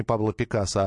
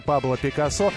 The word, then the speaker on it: Пикассо: Например, известная песня, которая с неправильным ударением произносится, не Пабло Пикассо, а «Портрет работы Пабло Пикассо».